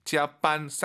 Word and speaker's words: 加班: 짜반, 쌍비읍보다는 약간 비읍 느낌으로 힘 빼시고 짜반 하면 좋을 것 같아요.